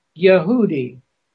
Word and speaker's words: Yehudi: It's pronounced the pronunciation he has is YEH dash H-O-O-D-E-E, and evidently, Strong is pronouncing it Yehudi.